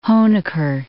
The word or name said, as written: Honecker